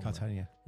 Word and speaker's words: Cartagena: Cartagena. How you pronounce it? I always spell it wrong. – Cartagena. – Right?